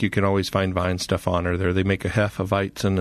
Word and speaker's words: Weihenstephaner: Weihenstephaner. – Weihenstephaner.